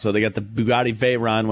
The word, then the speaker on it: Veyron: The Bugatti Veyron, did I say that right? – Yes, you did.